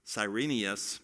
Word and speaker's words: Quirinius: In this lineage, you also have a man named Quirinius. Cyrenius might be a more accurate pronunciation of it.